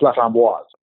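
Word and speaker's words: LaFramboise: You actually pronounced the E at the end of the name, so it's La Framboise.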